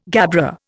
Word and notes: gabbro: Indian

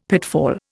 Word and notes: pitfall: Indian

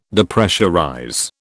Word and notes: Depressurize: British English

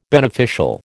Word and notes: beneficial: American English

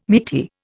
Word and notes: meaty: Indian

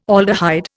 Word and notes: aldehyde: Indian

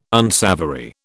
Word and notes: unsavoury: British English

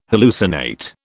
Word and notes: hallucinate: British English

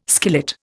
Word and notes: skillet: Indian

